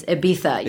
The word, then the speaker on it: Ibiza: it's Ibiza. You're saying it wrong. I'm like, I know, but we say Ibiza in the U.S.